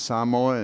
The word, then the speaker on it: Samoa: But yeah, we worked in Fiji and Samoa and Tonga. Did you hear how I said Samoa? That's how you say it.